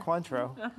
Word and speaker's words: Cointreau: Cointreau.